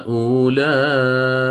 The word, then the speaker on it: أولئك: طيب والواو الساكنة التي أمامكم هذه ما بها؟ لا تلفظ، لا تنطق، لا يقاس عليها، زائدة في الرسم يعني، هذه زائدة في الرسم. فالمعول عليه هو اللام التي بعدها، ولذلك ما تقول أنت أولئك بل تقول أولئك، فالمعول عليه اللام المتحركة، ولذلك ذهبنا إلى القصر.